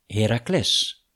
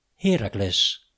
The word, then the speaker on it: Heracles: De oude Grieken legden het accent op de laatste lettergreep, Heracles. In het Latijn spraken de Romeinen de naam uit volgens hun eigen klemtoonregels, Heracles. Maar hier in Almelo zeggen ze Heracles.